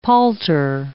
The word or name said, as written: palter